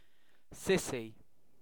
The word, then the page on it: sissy: British English